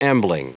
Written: ambling

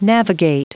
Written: navigate

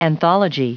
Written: anthology

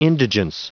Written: indigence

